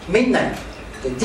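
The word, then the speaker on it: midnight: 英語でね、ミッナイト、 D は消えるんですよ。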